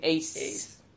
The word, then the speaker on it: AYCE: How would you pronounce that? AYCE. Ace.